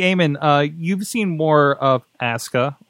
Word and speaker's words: Asuka: Okay. Japanese pronunciation. WWE is doing one thing right, because Japanese pronunciation, you never pronounce the U. Okay. Mm. But it's Asuka.